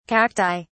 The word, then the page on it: cacti: British English